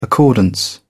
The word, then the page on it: accordance: British English